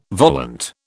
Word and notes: volant: British English

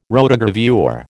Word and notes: rotogravure: American English

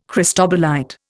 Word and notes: cristobalite: Indian

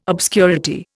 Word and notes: obscurity: Indian

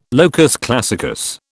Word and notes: locus classicus: British English

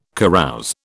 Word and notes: carouse: British English

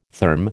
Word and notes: therm: American English

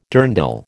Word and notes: dirndl: American English

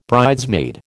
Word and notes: bridesmaid: American English

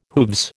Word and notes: hooves: American English

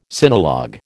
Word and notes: sinologue: American English

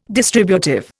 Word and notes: distributive: Indian

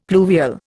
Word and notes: pluvial: Indian

